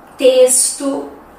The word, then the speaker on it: texto: Outro som que nós encontramos é o som do S. Por exemplo, as palavras máximo, próximo, texto se escrevem com X, mas tem som de S, ok?